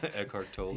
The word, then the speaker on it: Eckhart Tolle: Eckhart Tolle. – Yeah, Eckhart Tolle.